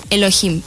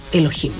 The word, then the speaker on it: Elohim: ¿Cómo se dice? – Elohim. – Elohim, mira, yo ni sabía.